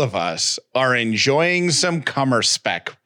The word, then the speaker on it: Kummerspeck: Cummer spec. – What is it? – Cummer spec. – Could you spell it, please? – K-U-M-M-E-R-S-P-E-C-K. Cummer spec.